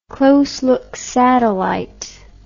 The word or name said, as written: close look satellite